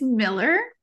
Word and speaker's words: Millar: it's Miller, but that's okay.